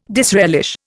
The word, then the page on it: disrelish: Indian